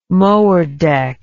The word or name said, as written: mower deck